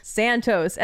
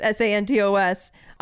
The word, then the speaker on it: Santos: I feel like I have to say that because every time I say my name, Sophie Santos, I get Sophia Sanchez. And I have to spell it out. So I'm spelling it out for everyone. I always get Sanchez.